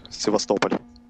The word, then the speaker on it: Sevastopol: Sebastopol. – Yeah, yeah, that's the city I'm living in. – Sevast- uh, it, in Russian, it would sound like Sevastopol.